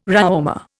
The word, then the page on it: RÂaumur: Indian